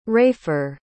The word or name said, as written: rafer